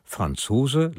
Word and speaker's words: Franzos: Auch bei ihm, wie bei seinem Roman, ist die Aussprache des Namens Franzos oder Franzos nicht völlig gesichert. Von Franzose leitet er sich etymologisch offenkundig her, wurde aber im Familienkreis mit Betonung auf der ersten Silbe ausgesprochen. Franzos. Für diese Aussprache haben wir uns letztlich entschieden.